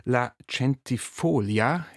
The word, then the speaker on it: La Centifolia: Und du hast auch ein eigenes Ensemble, La Centifolia. Ich habe extra nochmal gefragt nach der Betonung. La Centifolia gibt es seit 2019.